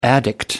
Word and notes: addict: American English

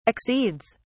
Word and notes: accedes: British English

